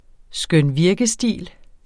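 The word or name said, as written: skønvirkestil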